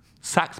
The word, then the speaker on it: Saxx: He takes out of his pocket a pair of PAX boxers. Sax boxers, sorry. – Didn't get the name wrong. – S-A-X-X, sax boxers.